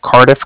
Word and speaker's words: Cardiff: Can you let him know that from the Bank of Cardiff called? And that's spelled C-A-R-D-I-F-F, as in Frank, so it's like car and indifferent.